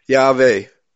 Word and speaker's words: Yahweh: The Hebrew Vav is like the English V, Yahweh. Is the correct pronunciation, like a V.